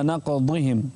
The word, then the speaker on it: نقضهم: انتبه إلى الضاد، انتبه إلى الضاد، فبما نقضهم. – نعم، لا تنطقها دال.